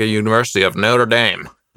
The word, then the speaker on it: Notre Dame: So what I'm actually talking about is like the Notre Dame, right? It burnt. – Can you pronounce that properly, please? – Notre Dame. – Thank you.